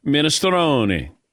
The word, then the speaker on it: minestrone: The soup is pronounced minestrone. Not minestrone, not minestrone, minestrone.